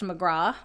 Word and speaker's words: McGrath: It looks like McGrath, but it's pronounced McGraw, I've heard.